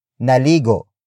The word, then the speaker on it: naligo: Word for word it's, naligo, ka, ba. Naligo, ka, ba. Naligo, ka, ba. The first word is, naligo. Naligo. Naligo. Naligo is spelled as N-A-L-I-G-O.